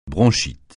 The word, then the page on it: bronchite: francais